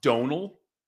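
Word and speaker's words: Domhnall: So I believe it, looks like D O M N H A L, but it's pronounced Donal.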